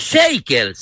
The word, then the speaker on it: Shakers: Los Jackers. – Los Jackers. – Sí. – Los Shakers serán. Se escribe Shakers, se pronuncia los Shakers.